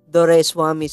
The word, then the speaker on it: Doraiswamy: Doraiswamy. So I just simplify it in 3 syllables. Door, rice, and whammy. So if you just combine those 3 elements, that's how you pronounce my name.